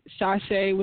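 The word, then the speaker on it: Chashé: It will be under Shashe, which is C as in Charlie, H-A-S-H-E as in Eric. And it does have, like, the little accent mark because, you know, that helps you pronounce it correctly.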